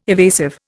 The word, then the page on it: evasive: Indian